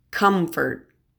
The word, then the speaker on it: comfort: This next short list of words also has a stressed COM, but the O is pronounced as a short U, so it's pronounced comfort, company, compass.